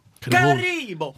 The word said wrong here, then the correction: Haribo: Sånn Haribo.